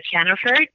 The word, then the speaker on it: Jennefer: It's uh, Jennefer, J E N N E F E R T B G.